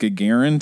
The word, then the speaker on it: Gagarin: We're talking about Gagarin today.